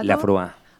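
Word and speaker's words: Laphroaig: ¿Has probado el, a ver si pronuncio yo esto bien, Lafroaich, whisky ahumado? – Lafroa. – Vale, pues eso.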